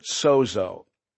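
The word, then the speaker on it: sozo (σώζω): Now, it's interesting that the word that's used in the original language that the Gospels were written, Greek, the word to heal or to make well, we would pronounce it sozo.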